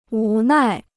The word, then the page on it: 无奈: wú nài